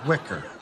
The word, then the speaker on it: Weicker: What are Weicker placemats? – It's pronounced wicker. – When it's spelled wicker... This says Weicker.